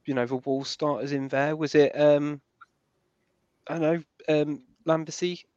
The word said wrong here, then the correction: Lambikisa: Lambikisa. There we go. – Just think of kiss at the end. Lambikisa.